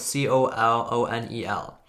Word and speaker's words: colonel: The word colonel is actually, you know, spelled C O L O N E L. And the way it is spelled, you would actually expect it to be pronounced colonel or something like that. Instead, it's pronounced more like K E R N E L.